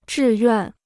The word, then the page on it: 志愿: zhì yuàn